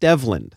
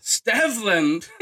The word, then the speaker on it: Stevland: It's so we've talked about this in depth, and it's pronounced, and I don't know if you think this Steveland? Is funny or less funny. Pronounced Stevland. – Stevland.